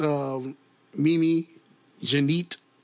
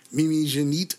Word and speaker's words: Mimi Janit: The R&B singer that I just recently, shameless plug, released, Mimi Janit, that's how you pronounce her name, Mimi Janit.